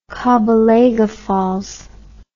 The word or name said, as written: Kabalega Falls